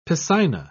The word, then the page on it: piscina: British English